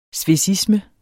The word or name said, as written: svecisme